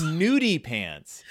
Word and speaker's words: nudie pants: Nutty pants? – It's nudie pants. – What? – Even though it's spelled with two D's in the audiobook, it's pronounced nudie pants.